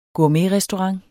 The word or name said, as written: gourmetrestaurant